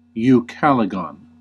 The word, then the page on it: Ucalegon: /juːˈkælɨɡɒn/